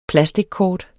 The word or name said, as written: plastickort